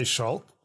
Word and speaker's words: Schalk: So it's not wrong the way you're pronouncing it. Let's start with that. It's traditionally, originally, whatever, a German name. And if you ask a German person to pronounce it just based on the letters, they will say Schalk.